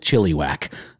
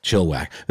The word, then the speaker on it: Chilliwack: I only say that because I did a podcast about this part of Canada once and I pronounced it Chilliwack and I got yelled at. So Chilliwack.